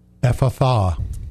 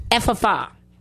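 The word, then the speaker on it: Ephphatha: Ephatha. – Ephatha.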